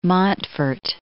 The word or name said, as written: Montfort